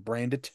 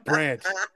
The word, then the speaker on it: Brandt: got one coworker who insists on pronouncing the D in Brandit. Brandt.